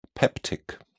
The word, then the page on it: peptic: British English